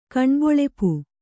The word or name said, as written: ಕಣ್ಬೊಳೆಪು